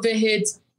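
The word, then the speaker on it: Vegetti: Tanto que chegou no último dia o Pablo Verretes, em algum momento, né, tentando aqui pronunciar. – Como é que é a pronúncia? – Olha, eu aprendi com jornalistas argentinos que é Verrete.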